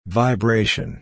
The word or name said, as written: vibration